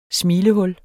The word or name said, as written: smilehul